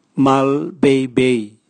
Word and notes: malbeibei: Raga

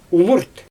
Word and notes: umurt: Xyzyl

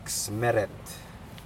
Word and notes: meret: Aneityum

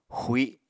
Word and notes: xui: Apatani

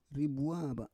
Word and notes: ribua va: Roon

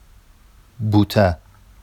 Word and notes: buta: Vafsi

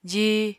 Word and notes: jí: apatani

